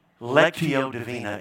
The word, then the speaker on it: Lectio Divina: Because Lectio Divina, I think is how you pronounce it. Say it again for me. Lectio Divina is what it's called.